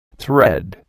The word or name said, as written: thread